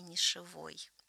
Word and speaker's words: нишевой: Слово зафиксировано на академическом орфографическом ресурсе «Академос», и правильное ударение «нишевой». – Нишевой, вот тебе на. – Вот так вот. Я сама говорю «нишевый», но мы должны сказать.